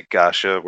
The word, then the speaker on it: gacha: Well, it can be pronounced that way, or it's pronounced gasha. Because the spelling is variant between G-A-S-H-A or G-A-C-H-A. So, I'm a weirdo and I say gacha where most other people say gacha. Which is also a fair pronunciation because gacha will gacha.